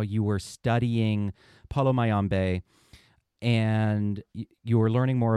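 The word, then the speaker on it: Palo Mayombe: Yeah, Paulo Mayombe.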